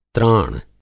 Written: त्राण

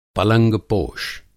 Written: पलंगपोश

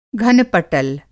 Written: घनपटल

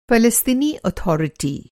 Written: अथॉरिटी

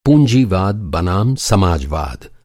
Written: समाजवाद

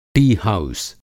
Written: टी-हाउस

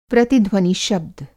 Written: प्रतिध्वनि शब्द

